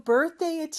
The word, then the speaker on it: birthday: 그리고 birthday의 th 발음에서 d로 넘어가는 거 birthday 그거 많이 연습해 주시고요.